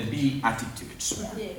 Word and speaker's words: Beatitudes: It's like beauty and beatitude. But I call it the Beatitudes.